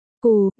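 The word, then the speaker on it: cù (酷): Ngầu, trong tiếng Trung phát âm là, cù, gần giống với phát âm của chữ, đắng, là, cù, nên ở đây tác giả mới chơi chữ, ngầu cần thêm đường, đắng cần thêm đường.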